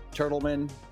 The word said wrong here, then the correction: Turturro: I can't pronounce his last name. – John Turturro. – Turtleman? Yeah, Turturro.